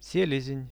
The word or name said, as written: selezen